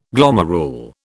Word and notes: glomerule: British English

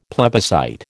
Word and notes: plebiscite: American English

